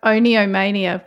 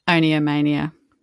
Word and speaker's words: oniomania: But, I mean, effectively compulsive buying disorder, and it's also called, now I'm going to say oniomania, which is probably very Australian way to say that, but it's spelled O-N-I-O, mania. Yeah. But then I think of the word onion and think, is it oniomania? – Oniomania.